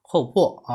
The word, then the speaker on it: 厚朴: 那今天呢，就从后破开始讲起。这个后破呢，首先说这个字的读音啊，不要读成朴，因为虽虽然这个字是朴素的朴，但是我们要读呢，要读成后破啊，它应该是破这个音。那我呢，经常读成后破，这是我发音不准啊，大家不要跟着我学。